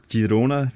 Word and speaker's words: Girona: Girona via Real.